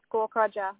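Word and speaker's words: Gaukrodger: Is that R-E-B-E-C-C-A? – Yeah. – Awesome. And then your surname? – It's Gawk Roger, which is spelled G-A-U-K. – G-A-U-K, yep. – Yeah, and then Roger, so R-O-D-G-E-R.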